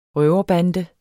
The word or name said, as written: røverbande